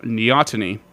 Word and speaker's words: neoteny: they go under a different process called neoteny, which is N-E-O-T-E-N-Y.